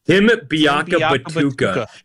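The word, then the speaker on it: Biakabutuka: How do you say it? – Tim Biakabatuta. He ran for like 290 yards.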